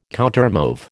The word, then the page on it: countermove: American English